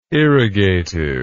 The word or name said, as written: irrigative